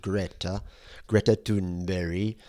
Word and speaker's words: Greta Thunberg: So this is the speech where Trump flies by helicopter to Davos, and that very morning, a 17-year-old pigtailed school dropout called Greta Thunberg, which apparently is how you pronounce it.